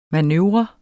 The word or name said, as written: manøvre